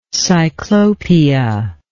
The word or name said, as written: cyclopia